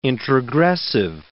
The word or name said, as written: introgressive